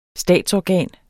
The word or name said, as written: statsorgan